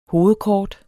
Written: hovedkort

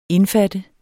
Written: indfatte

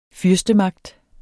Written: fyrstemagt

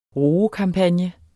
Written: roekampagne

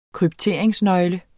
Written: kryptere

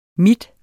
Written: min

